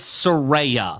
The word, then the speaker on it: Saraya: Saraya got the second biggest pop of the night, and it'll be Saraya or Saraya? – It's Saraya. – Saraya. – Per her Twitter, she explained how to pronounce it.